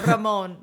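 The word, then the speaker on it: Ramón: With an accent mark over the O. – That was good. Ramon. Yes. Emphasis on the O.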